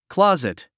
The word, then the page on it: closet: klɑ’zit; クロズィト